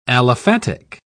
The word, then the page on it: aliphatic: British English